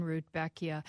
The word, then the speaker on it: Rudbeckia: Speaking of which, you're going to have to help my pronunciation here. My Rudbeckia? Rudbeckia? – Rudbeckia, yes.